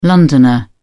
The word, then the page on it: londoner: British English